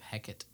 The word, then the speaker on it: Hecate: Nice pronunciation of Hecate there. – Is that right? – Yeah. – Oh. Okay. – Hecate.